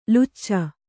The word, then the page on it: ಲೊಚ: loca; kannada